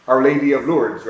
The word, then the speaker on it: Lourdes: The French call her Our Lady of Lourdes. They drop the S off.